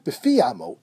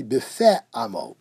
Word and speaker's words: b'fe amo: He says, it's not b'fi amo, as is in fact written in most Sidurim. He says the correct pronunciation should be b'fe amo, with a segol on the fe.